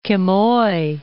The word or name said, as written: Quemoy